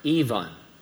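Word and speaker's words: Aoibhean: A-O-I-B-H-E-A-N. A-O-I-B-H-E-A-N. It's pronounced Yvonne. How would you pronounce this boy name? C-A-O-I-M-H-I-N. C-A-O-I-M-H-I-N.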